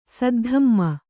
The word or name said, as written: ಸದ್ಧಮ್ಮ